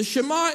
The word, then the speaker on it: Shema: If you're taking notes, it's spelled as a Hebrew word, S-H-E-M-A, Shema.